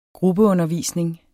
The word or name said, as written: gruppeundervisning